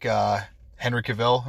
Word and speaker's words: Cavill: For myself, like I really like Henry Cavill.